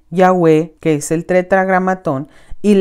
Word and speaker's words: Yahweh: En su libro santifiquemos tu nombre, admiten libremente en las páginas 16 y 18 que Yahweh es la mejor pronunciación del tetragramatón.